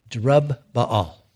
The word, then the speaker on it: Jerubbaal: I think I said that right. Jerubbaal. That's it.